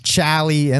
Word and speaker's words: Chally: As far as I know, it's pronounced Chally and the Filth Factory. – Chally. – Chally. Like, Chally and the Filth Factory. – Like Charlie without the R.